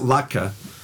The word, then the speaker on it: latke: But it's pronounced latke. – Latke, L-A-T-K-E.